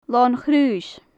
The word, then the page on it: lon-chraois: gaelic